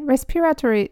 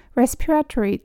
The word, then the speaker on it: respiratory: このね、 respiratory っていう単語、オーストラリアでは多分 respiratory ってみんな発音している気がするんですけれど、アメリカのね、 YouTube とかね、見るとみんな respiratory って言ってる気がします。なんか res の方にアクセントがすごくついて respiratory。